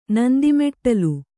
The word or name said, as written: ನಂದಿಮೆಟ್ಟಲು